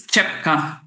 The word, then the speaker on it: Rytschepka: Genau, also mein Spiel ist Rytschepka. T-S-C-H wird das gesprochen.